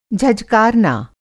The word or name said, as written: झझकारना